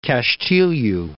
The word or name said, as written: Castilho